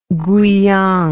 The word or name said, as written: guiyang